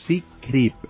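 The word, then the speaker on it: Secrypt: hay una aplicación que se llama Secreep. Se escribe Secreypt.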